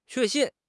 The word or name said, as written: 确信